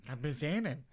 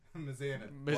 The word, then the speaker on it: Mizanin: Mike the Miz Mizarian. – Mizanin. – Mizanin.